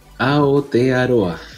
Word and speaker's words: Aotearoa: Oye, aquí encontré cómo se dice maorí, es Aotearoa. – Aotearoa.